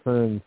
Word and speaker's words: Hearn: It was just that Bierce had heard of Dr. Hearn's theory, which coincidentally kind of rhymes with CERN, but it's Hearn with an H.